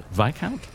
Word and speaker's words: Viscount: Viscount? – Remember, discount Viscount. – Discount. It doesn't make any sense. – Yeah, that doesn't help. – I remember because it doesn't rhyme with discount.